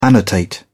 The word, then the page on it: annotate: British English